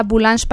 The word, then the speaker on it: Boulange: La página es La Boulange Paisana. Boulange se escribe como en francés, la panadería. – ¿Boulange? – Boulange es B-O-U-L-A-N-G-E.